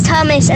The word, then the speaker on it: Thomas: It's spelled T-H-O-M-A-S, but some people spell it T-O-M-A-S. – Oh, they leave out that H. It's annoying, isn't it? – Is the H pronounced, though? – No. – Tomas.